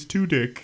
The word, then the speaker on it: Tudyk: Somebody in the video calls him Alan Tudyk, and he even in the video is like, it's Tudyk. – Yeah. – Which now that makes it easy. Today. Now I know his name is pronounced Tudyk.